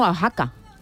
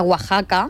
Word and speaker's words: Oaxaca: Pero a mí me ha recordado también Oaxaca. – A Oaxaca.